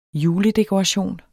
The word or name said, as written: JULEDEKORATION